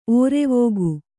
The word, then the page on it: ಒರ್ವಾಗ: orvāga